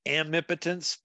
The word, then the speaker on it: amipotence: And tonight, we're going to talk about the death of omnipotence and the birth of omnipotence. Did I say it right? – I say it amipotence, but, you know, whatever.